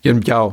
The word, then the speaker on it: Yuen Biao: Im Prinzip fast alles mit, du hast es, glaube ich, Yuen Bao ausgesprochen? – Yuen Biao. – Biao, genau, Yuen Biao.